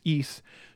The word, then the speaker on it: Ys: And then when you play a game like the one that's spelled with the letter YS, it's pronounced East.